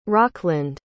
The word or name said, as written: rockland